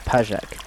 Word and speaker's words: PJAK: A mountain stream flows through one of the camps of the Party for Free Life in Kurdistan, more often identified by the acronym PJAK, which they pronounce Pajak.